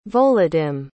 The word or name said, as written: volodymye